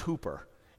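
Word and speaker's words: hooper (ὑπέρ): Now that word, in the original language, it rhymes with super, and it's hooper.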